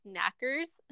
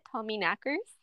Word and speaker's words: Tommyknockers: So mine is the Tommyknockers, and it's spelt knockers, but everything I've read says it's pronounced knackers. So that's how I'm going to say it, Tommyknockers.